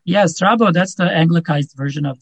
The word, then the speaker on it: Strabo: And if you pronounce the B in the correct Greek, it would be Stravo, but Stravo means crooked.